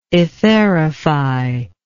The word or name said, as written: etherification